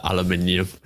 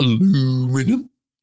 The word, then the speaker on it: aluminium: No, that aluminium. Yes, that's how you're supposed to pronounce it. Not aluminium. Can't pronounce it aluminium.